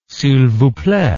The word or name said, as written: silvousplait